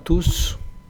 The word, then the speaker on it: tous: Todos, tous, es una excepción porque hemos visto que la S final normalmente no se pronuncia, pero en este caso sí, sí, es una excepción.